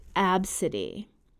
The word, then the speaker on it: Abcde: The name was spelled A B C D E and pronounced Absidy.